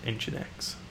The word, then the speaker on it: Nginx: I went to the Wikipedia page to make sure that my pronunciation was correct. And the pedants who edit Wikipedia have confirmed that it is, in fact, Nginx.